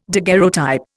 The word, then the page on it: daguerreotype: Indian